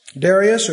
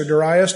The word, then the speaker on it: Darius: Darius, or Darius, depending on how you want to pronounce it, and Artaxerxes.